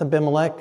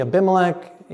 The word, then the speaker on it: Abimelech: that's Abimelech, that's Hebrew pronunciation, English would be Abimelech, Hebrew the Abimelech, king of the Philistines in Gerar.